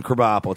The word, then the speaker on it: Krabappel: Yeah, pull up some Krabappel. It's spelled like crab apple with a K.